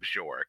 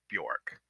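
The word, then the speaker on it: Björk: We don't pronounce her name Bjork. It's Bjork. – Bjork. – The J is a Y sound. So she is Bjork.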